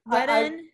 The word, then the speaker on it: Whedon: Whedon, is that how you say it? – Whedon.